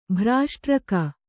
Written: ಭ್ರಾಷ್ಟ್ರಕ

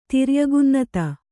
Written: ತಿರ್ಯಗುನ್ನತ

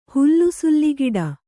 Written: ಹುಲ್ಲುಸುಲ್ಲಿಗಿಡ

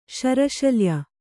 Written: ಶರಶಲ್ಯ

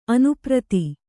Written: ಅನಪರಾಧ

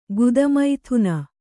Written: ಖದೀಮತನ

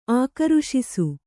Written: ಆಕ್ರೋಶಿಸು

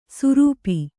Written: ಸುರೂಪಿ

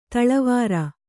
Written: ಧಾಳಿವರಿ

